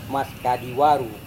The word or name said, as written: maskadiwaru